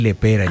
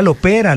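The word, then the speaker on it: Le Pera: Entonces, Gardel y Lepera. Yo, no era Lopera, lo he dicho mal toda la vida.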